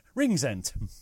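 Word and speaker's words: Rinxent: Ringsend. It's like ring piece, only Zent.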